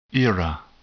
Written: era

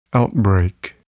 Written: outbreak

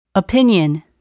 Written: opinion

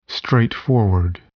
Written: straightforward

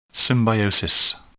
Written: symbiosis

